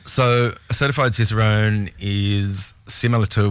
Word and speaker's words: Cicerone: You're a certified Cicerone. Is that the right? Is that how you say it? – Cicerone.